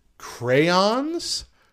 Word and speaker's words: crayon: It is crayon. – Yes. That's how I pronounce it, too.